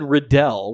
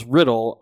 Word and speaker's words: Riddle: The correct answer is Tom Marvolo Riddle. So, man, I really don't. – Damn it. – Zach, you said Riddell, which would be R-I-D-D-E-L-E. And his last name is Riddle, R-I-D-D-L-E.